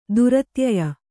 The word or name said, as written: ದುರತ್ಯಯ